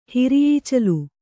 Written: ಹಿರಿಯೀಚಲು

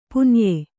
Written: ಪುನ್ಯೆ

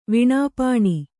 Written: ವಿಣಾಪಾಣಿ

